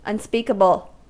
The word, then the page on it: unspeakable: American English